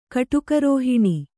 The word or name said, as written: ಕಟುಕರೋಹಿಣಿ